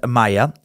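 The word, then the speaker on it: Maja: To figure out how to pronounce Maya, spelled with a J, M-A-J-A, I ended up finding a video of some strange old German little kids cartoon about a bee.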